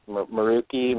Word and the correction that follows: Meraki: And he said Meraki. He corrected me. It was Meraki.